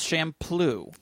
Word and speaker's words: Champloo: And it is Champloo.